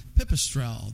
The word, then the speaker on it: Pipistrel: And right now, for our last segment, we're joined by some folks from Pipistrel. Did I say that correctly? – Yes, that's correct.